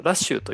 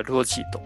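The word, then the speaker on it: 羅輯: ルオジーですね 2 巻の主人公これはあのラッシュと読めたりするんですけどもここではルオジーと読みます。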